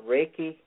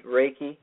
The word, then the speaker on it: Reiki: This might not take place until Thursday, but Reiki, I mispronounced it, Reiki.